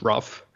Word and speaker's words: ROPH: The project I was working on, ROPH, which we pronounce rough with a bit of freedom of interpretation, there we were thinking about hostility very broadly, right, in the project.